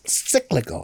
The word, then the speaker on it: cyclical: It's cyclical. – Cyclical. – Cyclical. I like that word. – Cyclical rhymes with biblical.